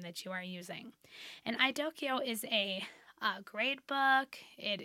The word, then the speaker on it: iDoceo: It is spelled I-D-O-C-E-O. I've also heard it referred to as Idoceo, but I'm pretty sure it's supposed to be Idoceo.